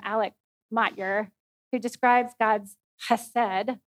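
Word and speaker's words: chesed (חסד): It's from the Hebrew word chesed. And if you're going to pronounce it correctly, you have to have a little phlegm in the back of your throat.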